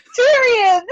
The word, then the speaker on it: Tyrion: Tyrion. Tyrion? – T- what? I said it and you still said it wrong. Tyrion.